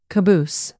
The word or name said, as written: caboose